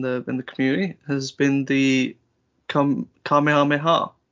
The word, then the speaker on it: Kamehameha: I thought you were gonna say it wrong then. – I was initially. I was gonna say it like I've seen some streamers that have said it. I've been a bit. – The Kamehama. – The Kamama Mama.